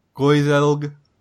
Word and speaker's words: Gaeilge: And we are going to talk about Old Irish, sometimes known as Old Gaelic or Gaeilge, if I pronounce that correctly.